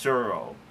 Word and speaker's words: Tsuro: Now, we already have one game from Calliope Games called, I think it's pronounced Suro, whatever, T-S-U-R-O, which we learned about from Will Wheaton's tabletop YouTube show.